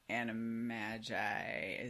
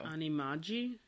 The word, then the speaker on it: Animagi: I'm going with the hard G sound because it's short for, like, a shortened version of magician, right? So animagi? Is that what you were going for? – Yeah. No? Well, I flattened it all out with my Midwestern vowel sounds.